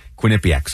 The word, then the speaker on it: Quinnipiac: Doug and I were there together, and we over breakfast went back and forth for a few minutes quizzing each other, making sure we could correctly pronounce Quinnipiac.